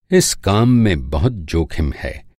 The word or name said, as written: जोखिम